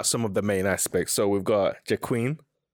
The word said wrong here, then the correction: Joaquin: I said it wrong, did I? – Joaquin. – Joaquin Phoenix.